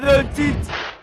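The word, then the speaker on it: Dončić: Luka, Luka, Doncic, Doncic.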